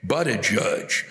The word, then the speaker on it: Buttigieg: Buttigieg, Buttigieg, Buttigieg. God damn it, it's Buttigieg. Fuck's sake. Here it is. Boo is in I'm fucking Casper Boo. T is in what you have with a biscuit or a piece of cake. And Dejeej, D-J-E-E-J, as in, oh, fuck it, what's the point?